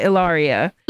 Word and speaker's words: Ilaria: It looks like Hilaria. Like I've heard people say. Wait, wait so her name's Hillary. – yeah, her name's like, Hillary. It's pronounced Ilari.